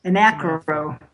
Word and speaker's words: AnachroCapitalistCast: And you are, of course, the host of the, am I saying it correctly, the Anarcho Capitalist Cast? – I'm close. Anacro. – Oh, it's a play on anarcho, but I use anacro, which I know I've only had two people catch that so far.